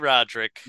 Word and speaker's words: Roderick: Roderick. – Randy Roderick. You have to really draw out the O. It's a long O. It's like Swedish.